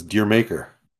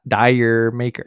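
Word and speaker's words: D'yer Mak'er: So that the correct pronunciation of that song is Deer Maker. – Dire Maker. No. Deer Maker.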